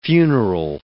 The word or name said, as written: funeral